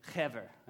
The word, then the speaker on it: Heber: Ally's name is Hever. I know it's fun to say. Hever, right? It looks like H-E-B-E-R, but that's how you say it.